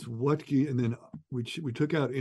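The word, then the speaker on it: Wuttke: It's not, you taught me how to pronounce it, Wettke, but that's not how you spell it.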